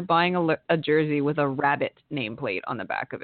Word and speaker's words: Rabbit: Not in the lineup for the Jacksonville Icemen is Wacy Wabbit. He will not be playing. I'm sorry. – Every time his name is actually Wacy Rabbit, but it's impossible to say his name without saying Wacy Wabbit.